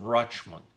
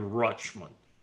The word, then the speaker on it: Richmond: I have in my mind the association Richmond-Rochmond, because that's how the real Richmonders pronounce it, Rochmond.